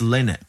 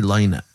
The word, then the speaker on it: Lynott: It's Lynott. Don't ever say Lynott. Phil Lynott, lad.